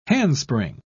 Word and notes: handspring: British English

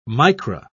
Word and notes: micra: British English

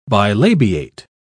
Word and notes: bilabiate: British English

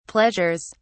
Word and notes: pleasures: British English

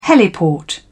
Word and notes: heliport: British English

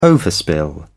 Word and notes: overspill: British English

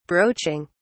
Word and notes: broaching: British English

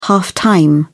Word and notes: half-time: British English

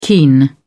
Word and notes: keen: British English

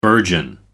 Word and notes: burgeon: American English

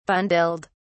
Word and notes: bundled: British English

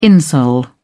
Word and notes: insole: British English

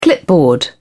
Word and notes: clipboard: British English

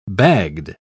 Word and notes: bagged: British English

